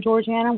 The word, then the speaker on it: Georgiana: Georgiana.